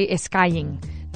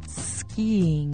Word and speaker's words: skiing: And remember, because this is a word that more than once I've heard Spanish people try to say esquiing. No, it's skiing.